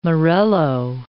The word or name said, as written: morello